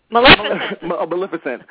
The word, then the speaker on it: Maleficent: Maleficent? I think that's how you pronounce it. – Maleficent.